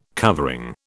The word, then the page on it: covering: British English